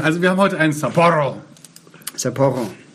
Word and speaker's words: Sapporo: Nein, die Betonung muss auf das Doppel-P hier sein. Sapporo.